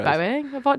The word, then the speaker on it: Bowie: I've always said Bowie. Do I say it wrong? – No, you are saying it right. It's me. I'm wrong. – Oh, okay. – I'm wrong. It's David Bowie.